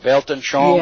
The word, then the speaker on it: Weltanschauung: But let me ask you right here, Carolyn, is he talking about the word that I've read that's, I think it's pronounced Weltanschauung? – Yeah. Yes.